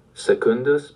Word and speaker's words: secundus: Secundus porcus parvus. Because secundus has a double consonant, the stress comes on the second syllable. Double consonants make the preceding vowel heavy.